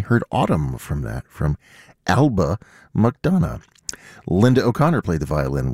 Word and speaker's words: Alba McDonough: That is music of Eba Madonna. I really promised that I was going to try to pronounce that correctly. Alba McDonough. There we go.